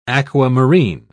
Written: aquamarine

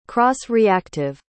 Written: cross-reactive

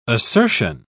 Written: assertion

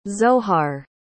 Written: zohar

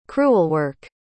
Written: crewelwork

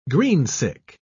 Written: greensick

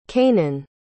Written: canaan

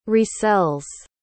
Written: resells

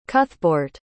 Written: cuthbeorht

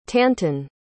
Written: tanton

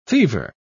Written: fever

